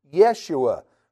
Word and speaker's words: Yeshua: Jeshua, that's the name we pronounce today as Yeshua, for those who are trying to pronounce Jesus' name in a Hebrew form.